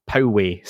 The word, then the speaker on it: Poway: So it's not Poway, San Diego. It's Poway, San Diego. And I await someone else getting back to me to tell me actually it's Poway.